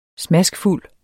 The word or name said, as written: smaskfuld